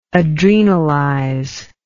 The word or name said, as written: adrenalize